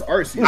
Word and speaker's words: Arceus: It's Arceus. – I said Arceus. – Okay, hold on. Did they say how it was pronounced in the games? – They pronounce it in the anime as Arceus. – They pronounce it in the anime, bro. It's Arceus.